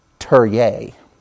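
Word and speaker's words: Türkiye: to change the pronunciation of their name. You know it as Turkey. They now, and I don't, can't even do it service, they want to be known as Turye.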